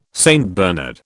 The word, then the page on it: St Bernard: British English